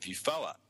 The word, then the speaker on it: VUFOA: And Andrew, about a year before, had created an organisation called VUFOA. That's how we pronounce it, Victorian UFO Action.